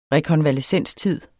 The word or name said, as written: rekonvalescenstid